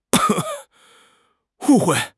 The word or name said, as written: wu4 hui4